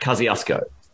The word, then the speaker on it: Kosciuszko: It is called Mount Kosciuszko, but I think in Polish it's pronounced Kosciuszko, but we say Mount Kosciuszko.